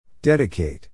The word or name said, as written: dedicate